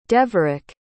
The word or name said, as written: deverick